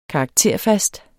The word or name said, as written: karakterfast